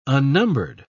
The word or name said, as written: unnumbered